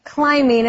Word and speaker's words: climbing: 有没有注意到这个字念成 climbing, B 不发音哦。